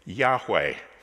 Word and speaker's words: Yahweh: Yahweh is how it's usually pronounced nowadays in Hebrew. In fact, the reason we're not entirely sure how it was pronounced is because it consists of four letters, four consonants, YHWH.